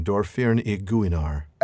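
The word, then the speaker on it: Dor-Firn-i-Guinar: And I hope I didn't butcher that name, Dorfirn y Gwynar. – It sounded right on to me.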